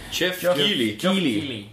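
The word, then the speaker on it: Keighley: Saatejuht on see, kuidas ta nüüd hääldati? – Jeff Keely. – Vot täpselt.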